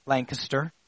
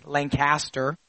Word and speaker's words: Lancaster: And I said it right, Lancaster. From Connecticut, we say Lancaster, but I had to learn over the years that it's Lancaster, just like it's not Reading Railroad.